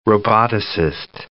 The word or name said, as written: roboticist